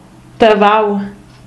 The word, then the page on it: Tavau: Romansh